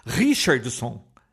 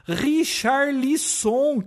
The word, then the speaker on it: Richarlison: Eu achava que era Richardson, né? Richardson. Richardson. O Richardson. Aí eu falei, por que, que ele tá falando isso? É, é, a pronúncia tá errada. Aí botou o nome do cara escrito Richarlison.